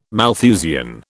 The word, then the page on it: Malthusian: British English